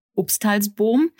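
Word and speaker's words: Upstalsboom: Das Unternehmen ist eine Hotelkette, Obsthalsbohm. Richtig ausgesprochen? – Ja, sehr gut, sehr gut.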